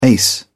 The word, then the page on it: ace: British English